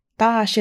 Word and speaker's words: Dosh: Dosh is pronounced T-H-O-S-H, an autumn word that means sun, day, or time.